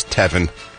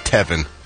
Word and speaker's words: Tevin: No, I said that right. It is Tevin. His name is Tevin.